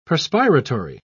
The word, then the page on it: perspiratory: British English